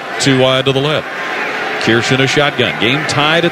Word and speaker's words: Kirsch: By the way, Brandon's last name is spelled K-I-R-S-C-H. But we were informed this morning when we got here it is pronounced Kirsch. Like Kirsch. – Yeah, K-E-E-R. Kirsch.